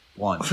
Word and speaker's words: Blount: LeGarrette Blount, say it right. – Blount.